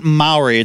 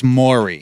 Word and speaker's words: Maury: It's not Maori. It's Maury.